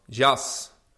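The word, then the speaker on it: Jas (جس): The word is pronounced Jas.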